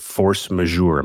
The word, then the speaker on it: force majeure: You've been tweeting a bunch about this, the idea of force majeure. Am I pronouncing it correctly? – Yes.